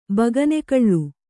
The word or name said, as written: ಬಗನೆಕಳ್ಳು